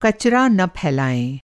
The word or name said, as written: कचरा